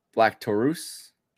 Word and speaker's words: Black Taurus: So we got Black Taurus.